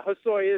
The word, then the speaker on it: Hosoi: Christian, am I saying that right? I've always said Hosoi. Am I saying it right? – Yeah, Hosoi is correct.